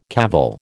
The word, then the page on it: cavil: American English